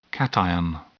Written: cation

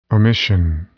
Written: omission

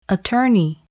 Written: attorney

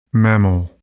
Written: mammal

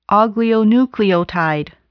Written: oligonucleotide

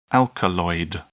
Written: alkaloid

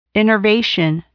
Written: innervation

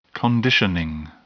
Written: conditioning

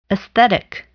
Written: aesthetic

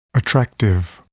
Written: attractive

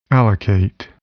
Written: allocate

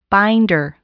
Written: binder

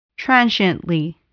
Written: transiently

